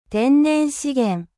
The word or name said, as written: 天然資源